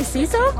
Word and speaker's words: CISO: How do you pronounce that abbreviation? – I would say CISO. – CISO? – Yeah.